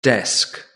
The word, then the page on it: desk: British English